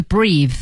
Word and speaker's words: breath: 哦，对，做动词的时候是 breathe。 – 对，所以大家一定注意哈，有 e 的时候 breathe 才是动词，它没有 e 呢，它只能读成 breath，它是个名词儿啊、哦。